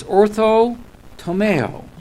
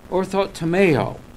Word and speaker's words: orthotomeo: And the best that I can pronunciate, since I'm not Greek, is orthotomeo. Orthotomeo. Ortho, you've heard of that before. Orthodontics. Or ortho, O-R-T-H-O-T-O-M-E-O.